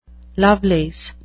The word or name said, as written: lovelace